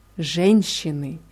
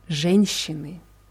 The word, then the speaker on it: Женщины: Женщины. Ja, die Betonung ist auf dem je vorne. Женщины.